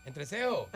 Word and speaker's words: entrecejo: ¿Entrecejo? Entrecejo y se escribe junto. No es eja, no se dice eja.